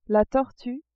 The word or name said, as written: tortue